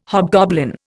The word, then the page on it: hobgoblin: Indian